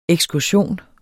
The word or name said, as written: ekskursion